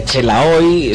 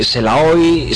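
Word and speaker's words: Xelaoy: Así es, este nombre ha dado lugar a multitud de pronunciaciones, ¿no? Desde Chelaoy, Selaoy, Salao, Shola, bueno, yo qué sé, ha habido un poquito de todo.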